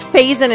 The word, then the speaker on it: Faison: Donald, I think it's Faison is how you say his name.